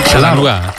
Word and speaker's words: Chattanooga: La ciudad de Chattanooga da la bienvenida a la selección nacional de España con los brazos abiertos. ¿Sí? – Ahí está, cómo se pronuncia bien, Chattanooga.